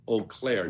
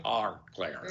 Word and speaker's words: Auclair: Now, notice the way that she pronounces it, too. Eau Claire, not our Claire.